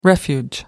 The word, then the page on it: refuge: American English